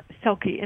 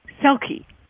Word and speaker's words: Selke: So, yeah, if you go to my website, Ilona Selke, and I'm going to spell my name because most people in America probably wouldn't know how to spell Ilona.